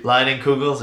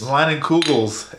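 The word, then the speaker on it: Leinenkugel's: Line and Kugels, if you want to send us a case. – Line and Kugels. It's so good. I said it right because I just copied what you said.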